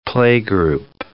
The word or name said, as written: playgroup